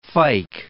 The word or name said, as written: fyke